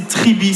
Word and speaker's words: Tribee: Si vous devez créer donc une cagnotte en ligne, choisissez Tribi, ça s'écrit T-R-I-B-E-E.